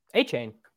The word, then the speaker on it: Achane: Correct the pronunciation, please. – Here's the thing. I started off the offseason calling him Devon Achane, and I don't know if I can get to A-chan.